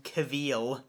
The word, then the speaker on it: Cavill: It's pronounced Cavill, you uncultured swine.